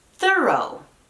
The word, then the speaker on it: thorough: Thorough. Thorough. Así es. La TH se mantiene suave, pero ahora estamos añadiendo una nueva sílaba y nuevos sonidos de vocal. Thorough. Thorough. Esto significa completo o exhaustivo.